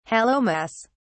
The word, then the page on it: hallowmas: British English